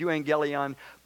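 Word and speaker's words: euangelion (εὐαγγέλιον): It's a strange word to pronounce, it's euangelion, but, but that word is hard to translate for us.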